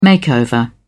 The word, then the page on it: makeover: British English